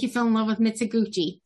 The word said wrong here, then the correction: Mizoguchi: Or how is his name pronounced? – Mizoguchi, played by Goro Fushitani.